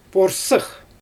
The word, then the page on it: porsyx: Xyzyl